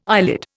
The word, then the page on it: eyelid: Indian